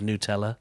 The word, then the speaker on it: Nutella: I read a little article again today which was saying that the correct pronunciation is Nutella.